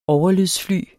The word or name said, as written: overlydsfly